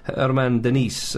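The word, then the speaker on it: Germán Denis: They've got a striker, Argentinian striker, called Herman Denis, up front for Atalanta, but his name is spelt German Dennis.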